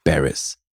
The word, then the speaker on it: Barris: Basierend auf einem Buch, einer Autobiografie, unauthorized wohlgemerkt, von Chuck Barris. Weißt du, wer Chuck Barris war? – Na, wenn du das S da am Ende nicht aussprechen würdest, dann... – Ja, nee, das gehört da dazu.